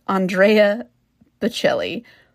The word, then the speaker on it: Bocelli: However, I realized, listening back, I completely mispronounced his name. So let me... Ed Sheeran did a duet of Perfect with Andrea Bocelli.